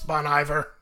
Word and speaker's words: Bon Iver: It's Bon Iver. – Is it Bon Iver? – Yes. – Wow. Bon Iver. That's why you didn't know what it was. You're like, I don't know what Bon Iver is.